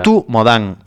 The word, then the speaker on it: Rutu Modan: Ruth Modan, es que es como se lee, o sea, como se pronuncia, Ruth, R-U-T-U, Modan, M-O-D-A-N.